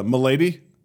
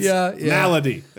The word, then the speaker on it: Malady: M-A-L-A-D-Y. And he's like, did you turn that into Milady? I was like, that is not pronounced Milady. – Maybe it is, though. – It is not, because the characters in the game, the voice actors, call her Malady.